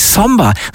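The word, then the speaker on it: Sombr: Es ist natürlich Sombre. So wird er ausgesprochen, aber am Ende steht ein R und kein A.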